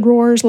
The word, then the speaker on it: Rohr: yes, and also I said this wrong earlier. You attended uh, Richard Rohr's Living School.